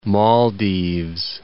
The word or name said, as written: Maldives